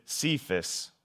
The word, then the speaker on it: Cephas: Now, he was originally named Simon, but during an interaction with Jesus in John 1:42, Jesus tells Peter his name will be called Kephas, or as we may pronounce it, Cephas, which translates to Peter, which means rock.